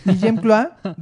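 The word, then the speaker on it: Guillem Clua: Eh, pero bueno, La golondrina es una obra escrita por, no sé pronunciar su nombre, ¿es Guillem Cloa? Sí. Ah, muy bien. – Guillem Cloa,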